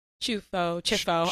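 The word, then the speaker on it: Ciuffo: Thanks for coming Shifu. – On. – Chifo. I even Chifo. Just said it wrong. I said my own last name wrong.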